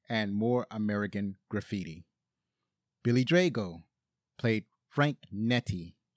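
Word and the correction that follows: Nitti: or I'm sorry, it's probably pronounced Neeti.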